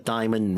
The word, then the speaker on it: diamond: 약속을 지키지 못했다 아직까지 완료시제 해주고 To buy my wife a diamond ring. 자, 다이아몬드 발음 좀 드릴게요. – Diamond. – Di, 그 다음에 mond. – Yeah, it's two syllables. It's not diamond, it's diamond.